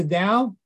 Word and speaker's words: Tao: It's spelled T-A-O or D-A-O. – Because you always say the Tao. – It says Tao. – No, but it's pronounced the Tao? – Yes, it's pronounced Tao by most people.